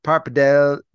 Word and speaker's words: pappardelle: It's the pappardelle pasta. I assume that's how you say it. – That's exactly how you say it. You have to say it that way or they won't give it to you.